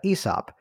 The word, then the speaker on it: Aesop: So while that's brewing in the background, and you corrected me on the pronunciation, so thank you for that, Aesop.